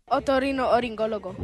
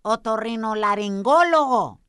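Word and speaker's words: otorrinolaringólogo: I know what it means, but the little kid said it wrong. Escucha lo que, como lo dijo. – Otorrino oringólogo. – Y es otorrino laringólogo.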